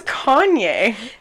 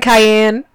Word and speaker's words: Kanye: It's Kanye. They literally said, "Good morning, Cayenne."